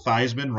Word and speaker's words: Theismann: Joe Theismann, you know, the great quarterback for the Redskins, it was Joe Theismann before he became a Heisman candidate at Notre Dame, and it became, well, Theismann rhymes with Heisman.